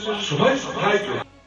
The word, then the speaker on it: Schweinsteiger: Aliás, como é que fala aí? Você que tem a pronúncia alemã correta. – Bastian Schweinsteiger.